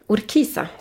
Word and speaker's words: Urquiza: Macarena Sanchez är en stridbar kvinna, hon är 27 år gammal och fick sparken från sin förra klubb, Urquiza. Urquiza, säger jag. Vi ska ju ha argentinskt uttal såklart, inte något spanskt läspande.